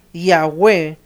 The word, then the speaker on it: Yahweh: Son solamente vocales. Y solamente no hay vocales. Y por eso tiene que tratar de pronunciar las palabras Yahweh, poner una A y una E, pero nadie sabe con certeza qué vocales se usarían en este nombre.